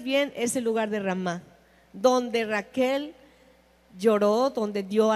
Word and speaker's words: Ramá: Entonces, Ramá, con acento en la A, significa angustia, llanto de una madre por sus hijos.